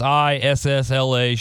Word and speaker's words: Isla: Somebody told me I was saying it wrong, and I feel like a real dickhead if that's the case, but follow them on Instagram. Check out their website, Isla Street Brewing, whatever. It's Issla Street Brewing.